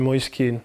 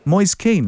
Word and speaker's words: Moise Kean: You just said it wrong again. – My name is Moise Kean. – Moise Kean. Like, Moise, you know, Kean. The two Manchester United. – David Moyes, Roy Kean. Legends.